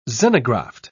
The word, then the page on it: xenograft: British English